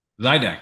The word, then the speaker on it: Zydex: This gentleman here has started, founder of PC Laptops, PC Laptops, um, Exetics. How do I pronounce that? – Zydex. – Zydex.